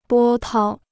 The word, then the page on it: 波涛: bō tāo